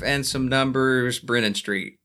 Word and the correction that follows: Brannon: 510 Brannon Street.